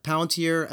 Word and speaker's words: Palantir: You mentioned that you worked at, I'm going to get this wrong, maybe Palantir. Is that how you pronounce that? – That's right. Yeah. – Yeah. Palantir.